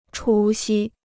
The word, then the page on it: 除夕: chú xī